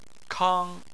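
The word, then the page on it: kang: tone 1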